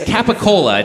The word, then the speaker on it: capicola: It's capicola. It's a mispronunciation, or not mispronunciation, perhaps a more authentic sounding Capicool. Pronunciation of Capicola.